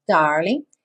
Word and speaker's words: darling: So, darling, sem pronunciar o G, darling.